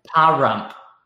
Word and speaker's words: Pahrump: Parump? – I know. There's Parump. A reason I skipped it. There's a reason I went right to Nevada and skipped over the town name. Pahrump. P-A-H-R-U-M-P.